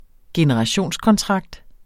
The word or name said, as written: generationskontrakt